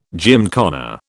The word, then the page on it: gymkhana: British English